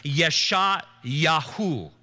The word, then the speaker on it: Yeshayahu: And as a matter of fact, the name Isaiah in the original Hebrew is pronounced Yeshayahu.